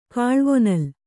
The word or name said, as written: ಕಾಲ್ವೊನಲ್